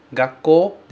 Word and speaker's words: gakkō: Bom, gakkō se escreve com OU, mas a gente fala como se fosse OO. Gakkō, gakkō, e não gakkō, ok? Esse OU sempre vai soar como O.